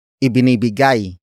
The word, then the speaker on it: ibinibigay: Ibinibigay, niya, ng, tubig, ang, manlalakbay.